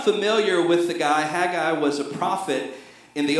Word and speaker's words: Haggai: Some people pronounce it Haggai, but we happen to have a professor of Hebrew sitting on the front row. So, Dr. Clark, how do we pronounce Haggai? – Haggai. – Okay, that's how you pronounce that.